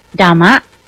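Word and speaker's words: Dhamma: ออกเสียงว่าดัมะดัมะแต่ออกเสียงเป็นตัวดีนะคะเสียงดอเด็กนะคะเสียงเดดัมะสะกดด้วยตัว D H A R M A D H A R M A ดัมะ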